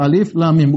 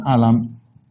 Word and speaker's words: Alif Lam Mim: Ketika Allah mengatakan alif lam mim, kita bacanya satu-satu alif lam mim, bukan kita baca alam. Ini bacanya alif lam mim. Bukan alam bukan alim, bukan alam ya.